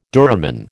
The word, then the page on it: duramen: American English